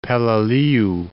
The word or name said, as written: Peleliu